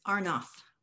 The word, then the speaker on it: Arnoff: I may have mispronounced your last name. Thank you for joining me today, Allison. – Glad to be here. Thanks for having me. And it's Arnoff.